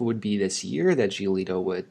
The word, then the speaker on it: Giolito: At the same time, I think Giolito, that's how you pronounce his last name, right? – I believe so. Okay. That's close enough.